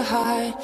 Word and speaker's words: heart: Para esta parte, observa que la pronunciación correcta de la palabra heart no es con una E, sino con una A. Heart.